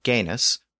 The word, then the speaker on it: genus: So we have the Latin word genus, which is the source of English words like genetics and genealogy. That original Latin root word was originally pronounced with that hard G sound at the beginning.